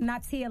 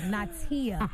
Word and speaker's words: Niatia: N-I-A-T-I-A. It's spelled like Nia-tia. No, so my name is spelled N-I-A-T-I-A, but it's pronounced Natia, like N-A-H. T-I-A, like Natia. not Natia or Niatia. Natia.